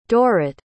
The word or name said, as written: Dorote